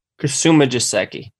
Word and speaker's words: Kasumigaseki: Kasumigaseki. – Kasumigaseki. – Yeah. – I missed a syllable.